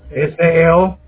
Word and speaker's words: SEO: Todos los que me han hablado de esto, el SEO, ¿es cómo? – SEO.